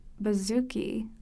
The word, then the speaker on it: bouzouki: And now I'm going to look up how to pronounce bouzouki. – Bouzouki.